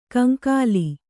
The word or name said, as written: ಕಂಗೋಲು